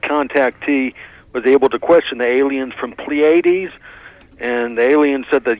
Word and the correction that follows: Pleiades: I believe those are pronounced the Pleiades, by the way, but it's a picky point.